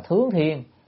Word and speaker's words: thướng thiên: Còn thượng thiên á, phải đọc là thướng thiên, là lên trời, đó.